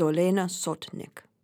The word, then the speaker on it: Olena Sotnyk: My guest today is Olena Sotnyk.